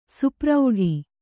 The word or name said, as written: ಸುಪ್ರೌಢಿ